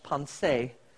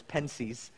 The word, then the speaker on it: Pensées: There was a mathematician, French, Blaise Pascal, mostly known today for a book he wrote, and he died before it was published, pronounced in French, pensée. I always pronounce it in English, pensées.